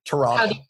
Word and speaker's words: Toronto: If you want to pronounce the hard T, us locals don't pronounce the second T. Toronto.